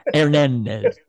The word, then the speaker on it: Hernandez: The silent H. Hernandez. So the more I look at it, the more I like the 4 than the 2.